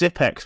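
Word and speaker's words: Xyp9x: It seems that Zipnix, Zipnix four-time major winning fame. – He's actually pronounced Zipex, but because he's bad now, we don't pronounce it properly.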